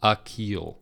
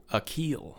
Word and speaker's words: Akeel: It's definitely the beginning syllable for Mr. Byers is like UH. – Okay. – So Akeel. – Akeel. – Yeah. – Okay. Not Akeel.